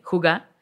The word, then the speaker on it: hygge: Entonces, esto es, hay un concepto danés que me encanta que se llama hygge, huga, H-Y-G-G-E, pero se pronuncia huga,